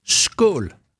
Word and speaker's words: Skål: Skål.